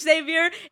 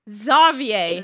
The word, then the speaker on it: Xavier: but it was spelled like Xavier, but it wasn't pronounced Xavier. It was pronounced Xavier. – It is because it is French. – That's the French. It is French. You have to say it with a French accent, you know.